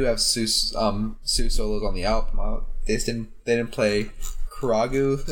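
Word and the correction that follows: Kagerou: Is that how you pronounce it? I forgot already. – Kagero. – Kagero, okay. Not Tattoo. Kagero, okay. Yeah.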